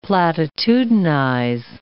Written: platitudinize

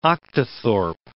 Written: octothorpe

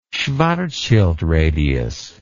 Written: schwarzschild radius

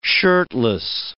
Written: shirtless

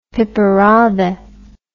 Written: piperade

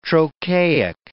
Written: trochaic

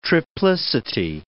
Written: triplicity

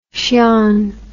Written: Hsian

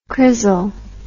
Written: crizzle

